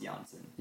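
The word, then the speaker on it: Yanchan: it's Yansen spelled like Yan Chan yeah.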